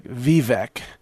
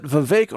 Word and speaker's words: Vivek: I was pronouncing it phonetically like Vivek. That's how it's spelled, V-I-V-E-K, but it's actually pronounced Vivek. It rhymes with cake.